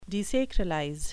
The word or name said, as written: desacralize